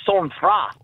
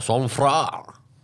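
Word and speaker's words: sang-froid: So the actual pronunciation is sang froid. – Oh, sang froid.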